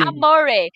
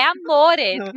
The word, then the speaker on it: amore: amore. Não quero amor, é amore.